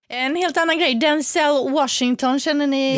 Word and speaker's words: Denzel: Det ska uppenbarligen inte uttalas med E utan med I. Vi kan ju lyssna hur det lät när han var Jimmy Kimmel här. – My name's pronounced Denzel.